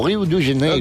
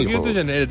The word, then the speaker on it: Rio de Janeiro: Començarem per Rio de Janeiro. Vinga, ho pronuncies, segur que... – O Rio de Janeiro. – Rio de Janeiro, exactament.